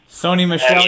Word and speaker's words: Sony Michel: And they did draft rookie running back Sony Michel, or Sony, however you pronounce it. – Sony Michel, and, yeah.